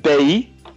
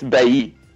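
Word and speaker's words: Bailly: You pronounce it Bay. And then I was actually on the radio this afternoon, about 6 o'clock, on Manchester XS or something like that. And they also, the presenter also said Bay. So I think it's Bay. – Bay. – I think in French, you don't pronounce the Y.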